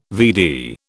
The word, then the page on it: VD: British English